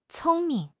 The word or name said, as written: cong1 ming5